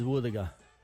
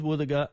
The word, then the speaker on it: wuthuga: Our local Mulgana word today is woothaga. What is a woothaga? Woothaga is a dugong. You can use woothaga in this sentence. Ngāda gamaru woothaga yōnga. I cooked dugong yesterday. Woothaga is spelled W-U-T-H-U-G-A. Woothaga.